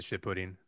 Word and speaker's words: Shippuden: Shippuden. – Yeah. That's really good. I don't like the original series, but once he's like older and they get into storylines. – Deep in the Shippuden.